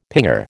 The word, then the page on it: pinger: American English